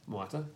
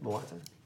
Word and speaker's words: Moata: Moata. – Very good. – See how I said that now? Moata. Nicely pronounced correctly, you know.